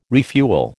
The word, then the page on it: refuel: American English